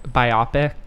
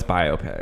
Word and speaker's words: biopic: But that's not right. – No, it's biopic. – Okay. – Because it's like a bio.